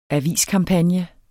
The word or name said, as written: aviskampagne